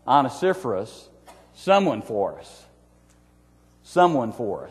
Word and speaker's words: Onesiphorus: His name was Onesiphorus. Say that fast 10 times in a row. Onesiphorus, Onesiphorus. And that's how you pronounce his name, Onesiphorus.